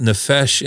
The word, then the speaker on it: nefesh: It's called nefesh in the Hebrew is how you would pronounce it.